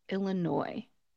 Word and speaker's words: Illinois: It is pronounced Illinois. Illinois.